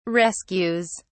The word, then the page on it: rescues: British English